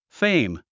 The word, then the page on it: fame: féim; フェィム